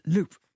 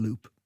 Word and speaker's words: Loups: There are these villainous characters in the book, spelt L-O-U-P-S. Yeah. Are you pronouncing them loup, as in the French, or English loop? – I think David would pronounce it loop.